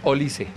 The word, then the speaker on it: Olise: La correcta pronunciación es Olise.